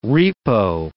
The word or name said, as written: repo